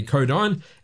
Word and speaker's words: Codyne: 1965-45 on White Whale Records, their cover of classic folk song Codeine or Codeine, this time certainly Codeine, as it's spelled C-O-D-Y-N-E.